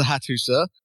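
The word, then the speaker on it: Hattusa: On the is it the Hatsusa? Here's what you've written. – Hittites. – Yeah. No, apparently it is the Hatusa, which is the capital of the Hittite Empire.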